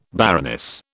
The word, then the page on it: baroness: British English